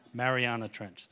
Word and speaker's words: Mariana Trench: It's called the Challenger Deep, and it's in the Manara Trench. I think I said it wrong. Did I say it? Mariana Trench, thank you.